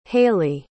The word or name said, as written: halee